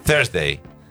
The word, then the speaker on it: Thursday: Thursday.